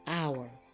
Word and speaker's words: hour: Column 3 also has the silent H at the beginning. Let's say these words. Air, herb, and sometimes people will say herbs, but most of the time the word is pronounced herb. Homage, honest, honor, and hour. They begin with H, but the H is silent.